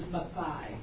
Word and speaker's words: Maathai: Her correct pronunciation is Maathai.